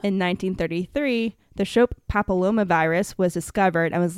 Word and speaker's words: Shope: Shope. I'm assuming it's pronounced Shope because it's S-H-O-P-E.